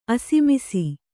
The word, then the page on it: ಅಸಮಿಸಿ: Kannada